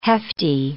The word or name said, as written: hefty